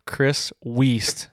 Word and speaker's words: Chris Weist: And today I've got a Pennsylvania native, Chris Weist.